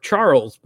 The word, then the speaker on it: Charels: And my recording name was Robert Charles, but it's spelled C-H-A-R-E-L-S.